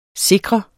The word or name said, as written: sikre